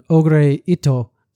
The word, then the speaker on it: Ogure Ito: If you put his family name before his first name, you get Ogure Ito. So the Japanese pronunciation kind of sounds like Oh Great. I'm going to try it again. Ogure Ito, so you can kind of hear it.